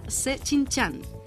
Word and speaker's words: Shijingshan: Departamento de Español, Radio Internacional de China, Avenida Chinchan, que se escribe S-H-I-J-I-N-G-S-H-A-N, número 16A, Beijing, código postal 100.040, República Popular China.